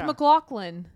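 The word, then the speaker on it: MacLachlan: Am I saying his name wrong? – Twin Peaks. – McLaughlin. Oh, it's McLaughlin.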